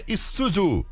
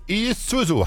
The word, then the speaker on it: Isuzu: É, Diego, você escreve Zuzu, mas a pronúncia é Isuzu. Isuzu.